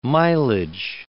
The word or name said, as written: mileage